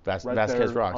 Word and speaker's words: Vasquez Rocks: Vasquez Rocks, on the 14.